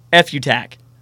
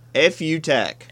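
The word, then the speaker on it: Futak: Literally pronounced F-U-tack. – F-U-tack. – F-U-tack. – Like F-U. – Okay. – But tack on F-U-tack.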